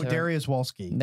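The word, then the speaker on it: Dariusz Wolski: I don't even know how to pronounce this. – Oh, Darius Wolski. – There you go.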